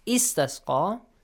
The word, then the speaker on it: استسقى: استسقى, you have to pronounce it. استسقى, because the third letter is having a fatha.